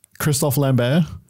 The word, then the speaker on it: Christophe Lambert: Starring Christophe Lambert, which is the correct pronunciation of his name, but most people just call him Christopher Lambert.